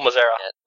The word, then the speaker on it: Mazzera: And Nathan Allen Knapp playing Nicole Zazma. – Good pronunciation there. Nicole Mazera. – Mazera. Nicole Mazera.